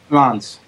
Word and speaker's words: Lens: Lens, thank you.